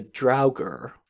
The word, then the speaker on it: Draugr: But it's interesting, and it brings me to another piece of mythology from Norse mythology, and they're called the Draugr. Again, weird pronunciation on that one. – Yeah, that looks right, I think. It sounds right for what I'm looking at, yeah.